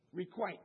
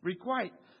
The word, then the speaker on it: requite: Requite. And so, long I, because you got the E at the end.